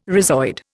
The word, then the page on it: rhizoid: Indian